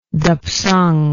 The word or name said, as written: dapsang